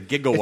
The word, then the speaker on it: gigawatt: So when it was actually a lot of people are surprised to find out that it's not JIGG, it's GI, it's a gigawatt.